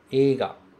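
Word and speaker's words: Eiga (映画): Aqui veja que o segundo kanji se pronuncia ga e o primeiro se pronuncia e. Se a gente for dividir, a gente pode deixar assim, ó. Eiga, eiga.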